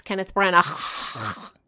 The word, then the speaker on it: Kenneth Branagh: I believe it's pronounced Kenneth Branagh.